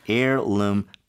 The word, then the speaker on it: heirloom: 그냥 piece가 아니라 heirloom piece가 나왔어요. – Okay. Now you don't hear the H, but it's spelled H-E-I-R-L-O-O-M. Heirloom.